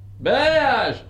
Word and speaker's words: balayage: Balayage.